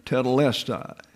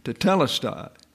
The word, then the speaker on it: tetelestai: Now, some people pronounce that tetelestai. Well, less is not accented. It's the T-E that's accented, tetelestai.